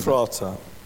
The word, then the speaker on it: Ephrathah: Ephrathah.